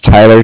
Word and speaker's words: Tyler: Like I said, my name is Tyler, T-Y-L-E-R, and I'm calling with Cardiff. It's spelled C-A-R-D-I-F-F as in Frank.